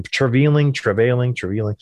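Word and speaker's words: Treliving: He's got a weird pronunciation of his last name. Treveling.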